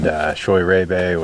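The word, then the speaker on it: Scheurebe: A small planting of Riesling, which I think is underappreciated as well, and Choy Rebe, which is a cross of Riesling and Sylvaner, or thought to be a cross of Riesling and Sylvaner. – That's how you pronounce that.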